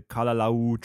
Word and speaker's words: Kalalau: But the real reason I want to go back is because I want to complete that 11-mile hike on the Kalalau Trail.